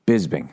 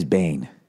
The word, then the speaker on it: Bisping: I realized when he won, I've been saying his last name wrong forever. UFC champ. Manchester. Here we go. What's his name? Michael Bisping. I always thought it was Bisbane.